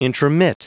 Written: intromit

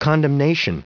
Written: condemnation

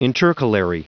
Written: intercalary

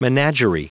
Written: menagerie

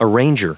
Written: arranger

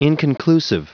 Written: inconclusive

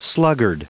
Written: sluggard

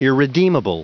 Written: irredeemable

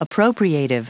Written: appropriative

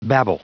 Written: babble

